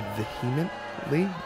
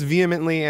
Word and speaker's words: vehemently: I was made aware by several people that in our first episode, The Other Side, I mispronounced the word vehemently. I think I said vehemently. – I heard it said both ways. – But I think it's vehemently, and I probably was just, my mind was going somewhere else, and I saw the H and pronounced it in my head.